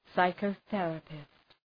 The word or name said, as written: psychotherapist